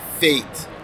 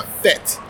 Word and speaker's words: fete: They have been pronouncing the party fate. Now, if you are from Jamaica, from Trinidad, it's a fete. So a fete. I'm looking forward to a fete tonight, you know, so I'm looking forward to that.